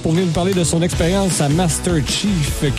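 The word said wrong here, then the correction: MasterChef: Québec, Master Chef. J'arrive pas de le prononcer en anglais. On dirait que mais c'est Master Chef. Je vais finir par l'avoir. Évidemment, on va parler de sa passion pour la cuisine et tout ça.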